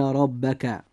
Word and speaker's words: ربك: نلاحظ أن حرف الراء في كلمة ربك جاء مفتوحا، لذلك يجب أن يفخم فينطق هكذا: إن ربك.